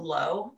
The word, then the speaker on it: Looe: Speaking of, when I was on honeymoon, I went and tried like a local coffee place in this town called Looe. – It's spelled mm. like Louis, L O O E, oh.